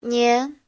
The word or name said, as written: nian2